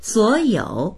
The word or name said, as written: suo3you3